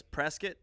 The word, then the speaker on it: Prescott: country bumpkin type because it's spelled Prescott, but everyone says Prescott.